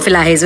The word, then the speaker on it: Elahe: E-L-A-H-E-H rehta kisi ka. E-L-A-H-E-Y. Mine is E-L-A-H-E, and my father actually put an accent on the last E, like a French accent, an acute accent, to get the pronunciation right.